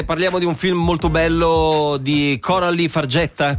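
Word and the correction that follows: Fargeat: Com'è che si pronuncia qui? – Farjà. – Farjà? Eh? – Sì, Coralie Farjà.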